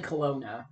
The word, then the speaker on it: Kelowna: So not long after I, which is excuse me, Lisa, who wrote the article, moved to Kelowna. Kelowna? Kelowna? – I think it's actually Kelowna. I think it's Kelowna. – pronounced Kelowna, yes.